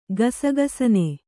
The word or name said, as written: ಗಸಗಸನೆ